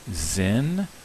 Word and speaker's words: Sinn: And the German word, I think it's pronounced as if it starts with a Z. Sinn means sense, mind, meaning, significance, spirit.